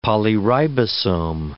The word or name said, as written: polyribosome